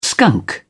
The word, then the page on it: skunk: British English